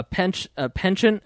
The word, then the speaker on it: penchant: So Adam Kokesh is clearly, according to the government's profile, probably a deranged psychotic domestic terrorist with loaded weapons, a penchant, is that how you say it, for violence? – Penchant. – A penchant? Yeah. – Penchant. – A penchant. – Was that French? – Yes, it is.